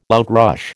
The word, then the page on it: outrush: American English